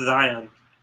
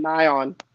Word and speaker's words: Xyon: It's spelled X-Y-O-N. Name's X-Y-O-N. – I think it's Zion. – Zion? – Yeah. – They were calling him Nion.